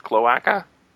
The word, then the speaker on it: cloaca: I thought they just had cloaca. Is that how it's pronounced? Cloaca? – Cloaca, the cloaca.